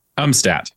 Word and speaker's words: Umstadt: Thomas Umstadt, have I said that correctly? – Umstadt. – Umstadt, okay. I was going a little bit German there, Germanic with Umstadt, but Umstadt will work.